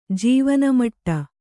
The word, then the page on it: ಜೀವನಮಟ್ಟ: jīvana maṭṭa